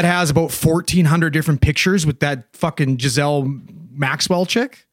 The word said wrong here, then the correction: Ghislaine: How do you say her name? – Dan will know. – Ghislaine? – It's Ghislaine, I think. – Ghislaine Maxwell. – Ghislaine Maxwell?